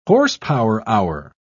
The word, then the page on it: horsepower-hour: British English